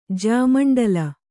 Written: ಜ್ಯಾಮಂಡಲ

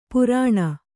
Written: ಪೈರಾಣ

